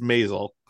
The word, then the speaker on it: Maisel: Oh, okay, it's Maisel. – Maisel. I don't know. I've seen the Marvelous Mrs. Maisel. – I've never watched the show. – Yeah, the Marvelous Mrs. Maisel had an excellent finale, and again another great finale.